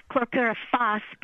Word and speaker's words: chlorpyrifos: So, first of all, chlorpyrifos, am I pronouncing it correctly? – Yes. I usually say it chlorpyrifos, but people say it in different ways. That's fine.